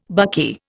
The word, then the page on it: buckeye: Indian